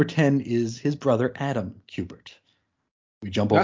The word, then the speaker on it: Kubert: 9 is Andy Kubert or Kubert. How do you say that? – Kubert. – Kubert, okay. – Like the video game.